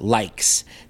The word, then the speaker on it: Lyxe: It is called Likes. That is spelled L-Y-X-E.